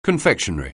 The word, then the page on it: confectionery: anglais